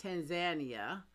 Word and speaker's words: Tanzania: I recently returned from a trip to Kenya, East Africa, and Tanzania, or I heard Americans have been saying it wrong, it's Tanzania, in East Africa.